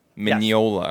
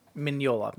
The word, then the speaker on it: Mignola: And the last one that I have here, Mike Mignola. Yes. – Mignola. Okay. – Mignola. – Okay. Okay. I got you. – Yep. – I got you. – That's how you say his name.